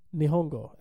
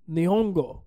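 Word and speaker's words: Nihongo (日本語): Nihongo, actually. – Ni, who you calling Nihongo? – Nihongo. – Nihongo. – You gotta say it like that.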